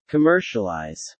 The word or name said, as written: commercialize